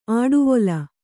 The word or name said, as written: ಆಡುವೊಲ